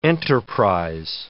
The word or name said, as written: enterprise